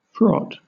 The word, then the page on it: fraught: British English